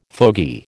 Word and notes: fogey: American English

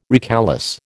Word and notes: recalesce: American English

